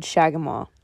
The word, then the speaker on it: Shagamaw: Oh, right, it's the Tote Road Shagamaw. – How do you spell that? – T-O-T-E hyphen R-O-A-D, and Shagamaw is spelled S-H-A-G-A.